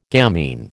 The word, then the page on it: gamine: American English